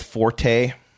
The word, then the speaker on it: forte: Like, I do that with forte. I believe the correct pronunciation of forte is fort. That's my fort.